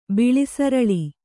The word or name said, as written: ಬಿಳಿಸರಳಿ